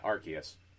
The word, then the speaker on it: Arceus: And Pokemon Legends Arceus, is that how you say it? – Yeah, Arceus.